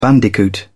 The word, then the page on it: bandicoot: British English